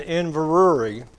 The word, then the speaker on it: Inverurie: And, of course, this is in Inverurie, as they pronounce it in the Scottish tongue.